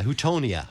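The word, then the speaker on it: Houttuynia: Hautognia cordata chamelon. – Not bad, yeah. Houtonia, yeah.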